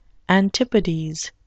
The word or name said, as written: antipodes